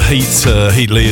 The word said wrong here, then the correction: Headley: as well. Or Headley, sorry.